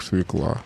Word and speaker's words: свекла: То есть со вчерашнего дня это слово произносится не иначе, как свекла.